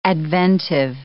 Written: adventive

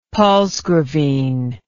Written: palsgravine

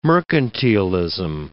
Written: mercantilism

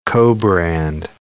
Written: co-brand

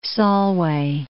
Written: Solway Firth

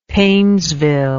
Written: painesville

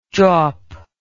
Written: jaup

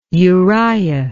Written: Uriah

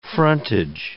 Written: frontage

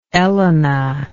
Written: Elena

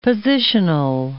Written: positional

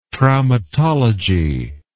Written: traumatology

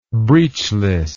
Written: breechless

